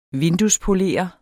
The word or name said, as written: vinduespolerer